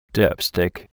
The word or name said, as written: dipstick